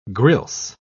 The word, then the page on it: grilse: British English